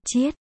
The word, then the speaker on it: Chiết: Lương Tư Cát Cát, Tào Diệp phình bụng cười to. Chấm, chữ đó đọc là Chiết.